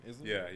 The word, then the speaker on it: Isla: The Tooth Fairy is Isla Fisher, I think that's how you say her name. Isla? – Yeah.